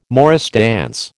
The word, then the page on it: morris dance: American English